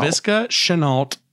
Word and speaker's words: Laviska Shenault: Laviska Shenault Jr. He's a stud.